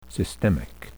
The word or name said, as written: systemic